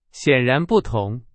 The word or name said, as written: 显然